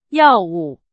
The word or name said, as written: 药物